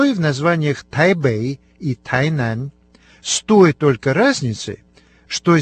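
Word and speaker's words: Тайнань (台南): Да, совершенно верно, конечно же, о городе Тайнань. Оба компонента этого названия произносятся со вторым тоном.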